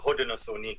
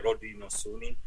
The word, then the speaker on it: Haudenosaunee: Peut-être que je corrigerais juste, je suis désolé, mais sur la prononciation de la Confédération, c'est plutôt Haudenosaunee, il faut dire. Ça, c'est en langue Onondaga, puis en Mohawk, il faut dire Haudenosaunee.